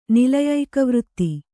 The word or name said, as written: ನಿಲಯೈಕವೃತ್ತಿ